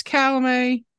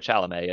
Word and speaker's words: Chalamet: I think it's Chalamet, but I could be wrong. – That sounds bad. Yeah, that or Chalamet. It's obviously the T is silent, but honestly, I'd probably put him up.